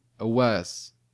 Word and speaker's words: Elwes: The L is before the W. – Elwes.